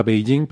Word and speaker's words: Beijing: Cuando realmente la pronunciación se parece más a Beijing, pero bueno, era la forma en la que ellos lo escribían.